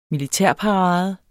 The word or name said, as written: militærparade